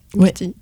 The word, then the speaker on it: Wittig: Wittig, ouais.